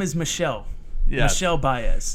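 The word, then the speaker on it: Michel: So he joins, and by the way, it's pronounced Michelle.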